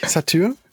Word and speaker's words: Satyr: Aber die Band heißt Satyr, glaube ich. Satyr. – Ja. – S-A-T-Y-R.